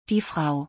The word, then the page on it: die Frau: German